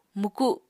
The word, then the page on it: mwku: Apatani